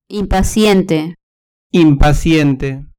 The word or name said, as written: impaciente